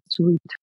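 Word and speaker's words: suite: До речі, ви знаєте, що правильно казати не тест сьют, а тест sweet.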